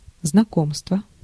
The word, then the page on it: знакомство: /znɐˈkomstvə/; znakomstvo